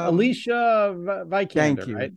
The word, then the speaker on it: Alicia Vikander: Vikander? – Alicia Vikander.